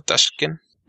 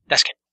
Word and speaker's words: Taschen: ¿Cómo se pronuncia? ¿Taschen? – Taschen. – Taschen, ok. – Es alemana la editorial. – Dale. – Así es que tú escúpelo y va a sonar parecido.